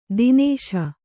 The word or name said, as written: ತಿನಿಶ